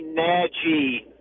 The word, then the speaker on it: Nagy: And so what I determined, fellas, is we are saying his name wrong. His last name, there was a question about how to pronounce it. I think it's actually Nagy.